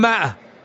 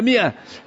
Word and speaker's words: مئة: المسلم لا يأتي للمصحف ويقرأ من غير شيخ، لأن القرآن رسمه يختلف عن الرسم، مئة مكتوب وراها ألف، ولذلك تجد العامة الآن ما يقول مئة يقول ماء، هي ما هي ماء، هذا الألف زائد لا ينطق. كم أيوة، إن يكن منكم مئة، مئة ما هي ماء، والعامة يقول ماء، ما هي ماء، هي مئة، لكن هذا الألف لا ينطق.